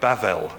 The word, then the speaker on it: Babel: In Hebrew it's written just using consonants, B-B-L, but the second B is pronounced more as a V. So I think today you'll find Jewish people might say Bavel instead of Babel.